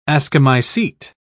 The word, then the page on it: ascomycete: British English